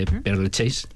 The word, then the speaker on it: Père Lachaise: Pero yo más que nada, de este cementerio, que el nombre se debe al monje confesor de Luis XIV, el nombre de Père Lachaise, ¿no? – Sí, sí. – Vale, lo he dicho bien,